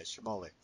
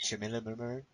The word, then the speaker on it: Schmoller: How do you pronounce your last name? – Schmoller. – Nah, there you go. Schmoller. I always say, like, Schmiller.